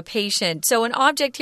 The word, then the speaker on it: object: But here it's the noun with the accent on the first syllable, object.